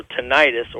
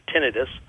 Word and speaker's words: tinnitus: and that's hearing loss and also tinnitus, or as some people refer to it, tinnitus. I've heard people refer to it both ways, and each claim that's the correct pronunciation.